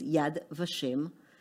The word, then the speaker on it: Yad Vashem: Okay, so the name is Yad Vashem. – Vashem, okay. – Vashem. It's two words in Hebrew that are taken out of the verse in Isaiah. Yad Vashem is the verse in chapter 56,